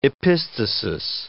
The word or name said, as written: epistasis